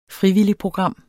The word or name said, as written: frivilligprogram